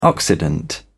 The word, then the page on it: occident: British English